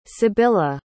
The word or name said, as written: Sybilla